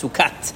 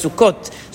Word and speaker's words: sukkot: What we care is how you pronounce it, and you never pronounce it as sukkat. You always pronounce it as sukkot.